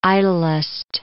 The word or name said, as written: idlest